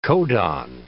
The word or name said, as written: codon